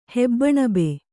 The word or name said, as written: ಹೆಬ್ಬಣಬೆ